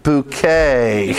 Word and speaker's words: Bucket: Who insists that her surname is pronounced Bouquet. Bouquet, not Bucket.